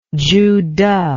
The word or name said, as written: Zhu De